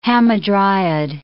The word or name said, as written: hamadryad